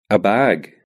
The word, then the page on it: bag: British English